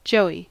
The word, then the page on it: joey: American English